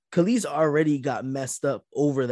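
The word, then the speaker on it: Kelis: Yeah, y'all heard about Kellis though.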